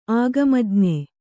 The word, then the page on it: ಆಗಮಜ್ಞೆ: āgamajñe